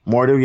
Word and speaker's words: Mordo: Mordo, yeah.